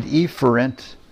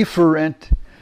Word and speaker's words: efferent: By the way, I looked it up on Webster where they pronounce the words for you, and the afferent is the proper pronunciation. Efferent has two pronunciations, but in both of them, the focus should be on the first syllable.